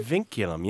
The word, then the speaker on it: vinculum: I'm going to mispronounce this, but it's a... – Vinculum? – Vinculum, yeah. It's a great Scrabble word, by the way. V-I-N-C-U-L-U-M.